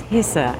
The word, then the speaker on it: Hessa: So it's our great pleasure to introduce Hessa.